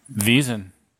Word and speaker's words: Wesen: So just say the word that you see and say it with a V and not a W. – Wesen. – Well, okay. So the E, when it's in between a consonant like that, mm-hmm. it becomes an A in English. So read it as a V-A.